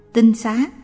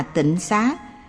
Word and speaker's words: tinh xá: Rất nhiều người đọc là tịnh xá vì nhầm lẫn giữa chữ tinh và chữ tịnh. Âm đọc gần nhau, ý nghĩa cũng có phần giống nhau.